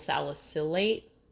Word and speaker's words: salicylate: The main active ingredient of Bengay is menthol, and then there's camphor and menthyl salicylate. Did I pronounce that correctly? – I don't know. – Salicylate? No, definitely know. A soft C.